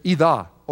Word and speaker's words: oida: It's ida, O-I-D-A. Some people pronounce it oida. It depends on how you learned it.